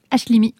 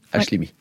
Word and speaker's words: Hachlimi: Hachlimi. La vie de château, c'est comme ça qu'on prononce ? – Hachlimi. – Hachlimi, ouais.